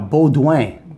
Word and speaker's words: Beaudoin: Thomas Bedouin. – Let me see. No, Baudouin. – Baudouin. – Yeah, that's a French name. B-E-A-U is Beau and D O I N. Baudouin. Thomas Baudouin. Baudouin.